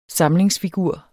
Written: samlingsfigur

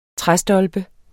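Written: træstolpe

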